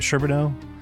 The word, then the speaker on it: Sherbino: Today I have with me Dr. David Charbonneau. Did I say that right, Charbonneau? – That's correct.